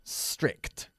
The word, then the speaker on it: strict: Some of my old school teachers were very strict. No se puede decir estricta. Cuidado con la pronunciación.